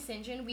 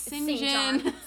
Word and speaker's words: St. John: We're saying St. John. We mean St. John. – It's pronounced St. John.